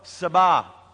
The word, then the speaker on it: Sabaoth: The T is silent, as I understand it. It's Jehovah Sabaoth.